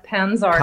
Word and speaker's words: Pantzar: Do you say Pantzar? – Yeah, actually, in English, I say Katja Pantzar.